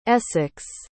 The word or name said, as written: essex